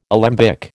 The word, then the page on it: alembic: American English